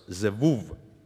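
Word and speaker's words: Beelzebub: Heebre keele hääldus oleks Zevuv.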